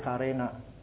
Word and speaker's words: kerana: We spell it as K-E-R-A-N-A. In Indonesia, it's spelled as K-A-R-E. So we say kerana, they say karena.